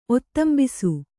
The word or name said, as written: ಒತ್ತಂಬಿಸು